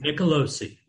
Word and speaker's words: Nicolosi: Nicolosi. – You were close. Nicolosi, just like it's written.